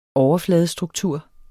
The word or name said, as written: overfladestruktur